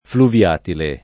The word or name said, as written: fluviatile